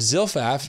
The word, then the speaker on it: Zilpah: Laban gave his female servant Zilpah, is that the right, that's the right pronunciation, to his daughter Leah to be her servant.